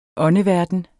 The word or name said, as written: åndeverden